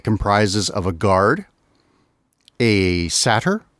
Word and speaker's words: satyr: I think I remember them pronouncing it satyr in The Last Unicorn. So that's kind of okay. That's kind of what I go with. – Yeah. Okay. A satyr.